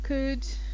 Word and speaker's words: could: You've got should, could, would, all of these O-U-L-D words, you don't pronounce the L. So it's not could I have a cup of coffee please, or would you mind helping me? It's could I have, would you mind? So it's like that L isn't there at all.